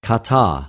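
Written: κατα

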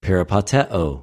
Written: περιπατεω

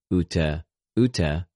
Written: οὐτε...οὐτε

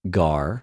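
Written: γαρ

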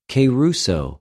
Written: κηρυσσω

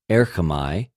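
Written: ἔρχομαι

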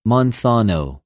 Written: μανθανω

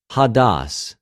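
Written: ὁδός

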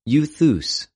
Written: εὐθυς

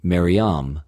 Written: Μαριαμ

